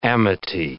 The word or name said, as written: amity